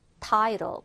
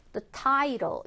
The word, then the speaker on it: title: 우리가 제목을 영어로는 title, 발음이 이렇게 들렸죠? The title, 이렇게 들려요. 그래서 가운데 트 발음이 R로 약화되는 현상이고요.